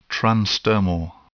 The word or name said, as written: transdermal